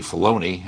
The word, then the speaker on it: Filoni: Filoni. And it's actually Filoni. I keep mispronouncing his name.